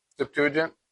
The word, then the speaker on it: Septuagint: The Greek translation, how do you pronounce Septuagint? – Septuagint.